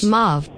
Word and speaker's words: mauve: The word M-A-U-V-E is pronounced mauve, rhyming with stove in British English, and more commonly mauve, rhyming with the first part of awful in American English. – Oh, there you go. – So if you're speaking American, then it's pronounced mauve.